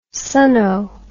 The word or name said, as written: sonno